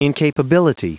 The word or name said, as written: incapability